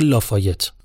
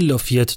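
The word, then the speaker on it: Lafayette: یکی دو نفر بهم گفتن که تلفظ این شهر میشه لافایت اما نه همین لافیت درسته لافایت یه شهر تو آلاباماست اینی که من دارم میگم لافیته که تو ایندیاناست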